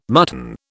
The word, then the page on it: mutton: British English